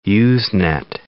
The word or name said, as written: Usenet